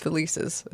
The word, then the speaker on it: Felicis: Am I saying that right? – I think it's Felicis. – Felicis? – Felix Felicis is how the book said it.